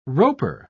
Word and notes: roper: British English

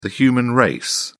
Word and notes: human race: British English

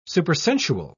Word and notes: supersensual: British English

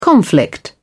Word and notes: conflict: British English

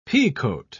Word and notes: peacoat: British English

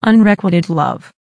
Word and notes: unrequited love: American English

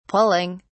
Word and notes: pulling: British English

